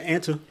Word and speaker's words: Anta: Something called Anta shoes? – Oh, there you go. Anta, my bad. There you go. – How you spell it? – A-N-T-A.